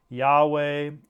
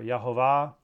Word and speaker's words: Yahweh: The first is Lord in all capitals, L-O-R-D, all caps, which is the key for us to know that the Hebrew word Yahweh or Yehovah, those are the two common pronunciations, or Jehovah is another anglicized version of that pronunciation.